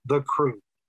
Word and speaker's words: Krewe: And it's called The Crew, and it's spelled K-R-E-W-E, as in a Mardi Gras crew.